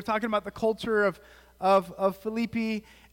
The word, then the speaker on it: Philippi: Now Philippi, it's, I was talking to my Greek friend last night, and I was corrected that it's Philippi, is how you say it the right way, and there's a lot of like, even me saying that right there, Philippi, is not necessarily correct.